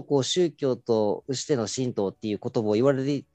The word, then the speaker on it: 神道: まあ、前回、申し述べたこととかなり重複するところが出てくるとは思いますけれども、まあ、神道というのは、まあ、神の道と書いて、まあ、神道と読みますけれども、まあ、日日本にまあいらっしゃる八百万の神様をお祭りするまあ宗教になります。